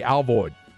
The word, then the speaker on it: Alvord: I'm telling you, the old timers say Al Void up there, but there's no I in that. So I would split the difference and pronounce the I like an A, Al Void.